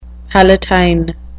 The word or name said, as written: palatine